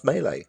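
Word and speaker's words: mêlée: Although I see here that it is more correctly pronounced melee. – Yes. It's a French word.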